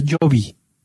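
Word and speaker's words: Jobe: Se pronuncia Jovi.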